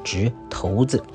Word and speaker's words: 掷骰子: 那么上一周跟各位介绍了关于掷骰子啊，就是我们讲正确读音应该讲成这个掷骰子。